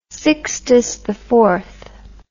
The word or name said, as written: Sixtus IV